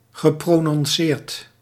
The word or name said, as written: geprononceerd